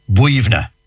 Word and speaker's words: Boyunagh: Well, you see, colloquially, like, for example, I know one, for example, one cemetery near Glenamedy, and it's spelled B-O-Y-U-N-A-G-H. B-O-Y. So you'd be saying Boyuna. No, yeah. Not a bit of it. B-O-Y-U-N-A-G-H is pronounced Buivna.